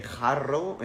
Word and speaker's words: Jarrow: Eh, si estás en mi país, supongo que estás en Latinoamérica, tienes acceso a una marca que es Jarrow, eh, Jarrow, pronunciación español, que lo puedes tomar.